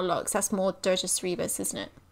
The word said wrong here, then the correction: Cerberus: Or Cerberus, I'd never say that right. – Cerberus.